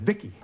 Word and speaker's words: Vicki: V-I-C-I, I pronounce it Vicki.